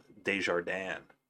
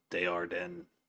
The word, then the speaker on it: Desjardins: The name in French is pronounced Desjardins. But they pronounce it here, like, Desjardins.